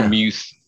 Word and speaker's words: Freiermuth: The Freiermuth. Is that how you say it? – Yeah. – Freiermuth.